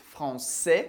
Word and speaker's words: français: A mesma coisa vai acontecer com as palavras terminadas em S. Como, por exemplo, français. Français quer dizer francês. Français, a gente não pronuncia o S.